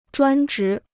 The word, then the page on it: 专职: zhuān zhí